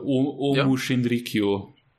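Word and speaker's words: Aum Shinrikyo: Und zwar, um das kurz in einen Kontext zu rücken, 1995 hat die, wie spricht man das, Ohm-Sekte spricht man es aus, die jetzt Aleph. – Also ich kenne sie halt unter Omu Shinrikyo.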